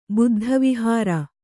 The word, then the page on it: ಬುದ್ಧವಿಹಾರ: kannada